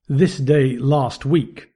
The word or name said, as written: this day last week